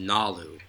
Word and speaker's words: Galu: Like, his dad's name is spelled G-A-L-U, but it's pronounced Nalu.